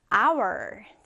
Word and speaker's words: hour: 자, 여기서 여러분 h로 시작하는 단어인데요. 지금 h 발음 쌤이 했어요? 하지 않았죠. 그냥 hour이라고 했습니다. 자, h는 바로 silent letter. 네, 발음이 되지 않는 무음이에요. 무음. 그래서 hour이라고 발음한다라는 거 주의해 주시고요.